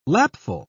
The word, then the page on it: lapful: British English